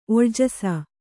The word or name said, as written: ಒಳ್ಜಸ